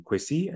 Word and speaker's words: Kwesi: So, for example, my proper name is if you're deep in the forest, is Akwesi, is an A in front of the K, okay. Right? Akwesi. But you know, in other areas, it just being Kwesi, and on the coast, it's Kwesi spelled with an E rather than an A, so it's K W E S I. – Okay. – And so there's slight phonetic, you know, nuances depending on location, right?